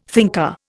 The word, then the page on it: thinker: Indian